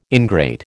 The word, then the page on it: ingrate: American English